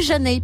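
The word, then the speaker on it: Zhané: elles sortent leur album Pronounced Janey. Voilà, ça veut dire prononcer Janey, parce que c'est vrai que ça s'écrit Z-H-A-N-E, donc on dirait pas ça comme ça,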